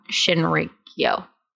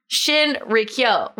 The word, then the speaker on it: Shinrikyo: I was abducted by Shinrikyo. No, I said it wrong again. That's why I keep avoiding the last word. Shinrikyo.